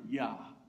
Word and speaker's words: Jah: And then the last three letters of his name, J-A-H, Jah. It's a shortened Hebrew name for the word of God, Jehovah, Yahweh. You would also say it like this sometimes, Yah. The Hebrew would pronounce it Yah.